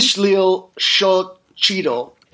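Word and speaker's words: Ixtlilxochitl: How do you say his name? Ishlil Xochitl.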